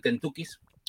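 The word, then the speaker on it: Kentukis: Es un librito a medio camino entre el fix-up, la novela, la recopilación de relatos, que es el Kentucky's, pronunciado así porque está escrito así, ¿no? Kentucky's de Samantha Shevlin y publicado por Literatura Random House.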